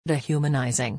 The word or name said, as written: de-humanising